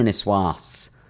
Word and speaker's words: niçoise: I mean, it's tuna niçoise all the time at Pret, right? – Yes. – Did I pronounce it right? – You did. Well done.